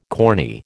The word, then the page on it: corny: American English